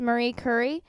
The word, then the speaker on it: Marie Curie: Now, I don't know if I'm pronouncing this right, but I believe it's Marie Curie.